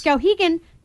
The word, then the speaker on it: Skowhegan: Is it Skowhegan? Is that how you pronounce it? – Yes. – Skowhegan.